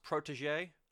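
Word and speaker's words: Protoje: I believe it's pronounced Protégé. That makes me... I'm pretty confident. It's not spelt like Prodigy, but, you know.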